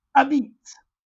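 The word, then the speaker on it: apizza: Instead, they use a pizza run together, which is pronounced a beats, Neapolitan dialect slang.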